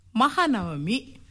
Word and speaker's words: ಮಹಾನವಮಿ: ಆಗ್ಲೇ ಗೌರಿ ಕಳೆದು ಮಾರ್ಲಾ ಮೀನು ಮುಗೀತು, ಇಷ್ಟೊತ್ತಿಗೆ ಪೈರು ಹಂಗೆ ತೂಗಿ ಬಾಗಿ ಸಂಭ್ರಮ ಪಡ್ತಾ ಇರಬೇಕಾಗಿತ್ತು. – ಅದು ಮಾರ್ಲಾಮಿ ಅಲ್ಲ ಕಣಪ್ಪ. – ಇಬ್ರು ಸರಿಯಾಗಿದ್ದೀರ, ಅದು ಎರಡು ಅಲ್ಲ, ಮಹಾನವಮಿ ಅನ್ನೋದು ಸರಿಯಾದ ಉಚ್ಚಾರಣೆ ಗೌರಮ್ಮ.